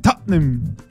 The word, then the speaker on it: Tottenham: Y el Tottenham le dicen Tottenham.